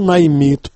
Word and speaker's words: наймит: По-русски слово «наймит» произносится с ударением на последнем слоге. Вы же, читая когда-то мое письмо, произнесли его по-украински, то есть с ударением на первом слоге.